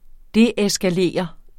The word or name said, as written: deeskalere